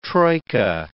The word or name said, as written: troika